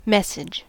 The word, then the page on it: message: American English